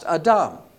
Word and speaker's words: Adam: And why is it important that we pronounce it Adam instead of Adam? It's the same letters if you look at it in English. Well, in Hebrew, it's pronounced Adam. And that's important because he came from Adamah.